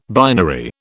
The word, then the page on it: binary: British English